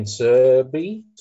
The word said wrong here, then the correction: Sabir: I think that's pronounced that way. – Ben Sabir. – Sabir, there we go.